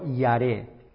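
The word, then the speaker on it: yare (ירא): Dice, raíz yare. Se pronuncia casi en el hebreo, yare.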